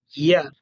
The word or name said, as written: y